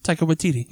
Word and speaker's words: Taika Waititi: I mean, Again, I think that acknowledging that different writers have used him differently, I think that Taika Waititi tapped into those moments. – How do you say his name? Taika Waititi, yeah.